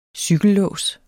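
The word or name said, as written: cykellås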